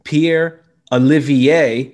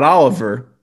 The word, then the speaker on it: Olivier: Pierre Olivier, not Oliver Joseph.